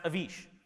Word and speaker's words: Avish: It's pronounced Avish. But I get all sorts of variations. I get Avish, Avish, Ashvin. One time someone called me VJ. It's like, you're not even trying.